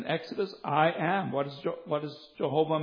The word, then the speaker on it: Jehovah: Jehovah is the name we use to refer to as our covenant-keeping God. Sometimes you'll hear somebody say Yahweh. It's a more modern pronunciation.